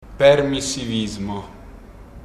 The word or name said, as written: permissivismo